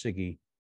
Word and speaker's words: CIGIE: And I would say central to that is the Department of Justice, the SIGI, and it's C-I-G-I-E. It's pronounced SIGI, but C-I-G-I-E.